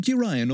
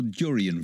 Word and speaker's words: durian: and the Siamese cat and the durian. Is it durian or durian fruit? – Durian, yes. – Durian. I mispronounced it.